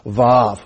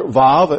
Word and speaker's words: Vav: It looks like this is a vertical line with a yod attached to it on the top there, but it is pronounced, I believe, Vav.